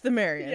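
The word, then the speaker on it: Pthumerians: P-T-H-U-M-E-R-I-A-N-S. I think it's a silent P, if I'm remembering correctly. – But Thumerians. – I'm pretty sure it's Thumerians.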